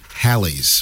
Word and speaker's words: Halley's: And the other thing is it's actually Halley's Comet. I think it's been mispronounced. – Well, thanks. – But I mean, everybody says it that way, Halley's Comet. But it's, you know, and Bill Haley that had Rock Around the Clock, that was his name, and it was named after the comet. But it's named after my daughter. – It's actually pronounced Halley's.